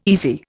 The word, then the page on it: easy: Indian